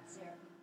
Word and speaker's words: Zarephath: Zarephath.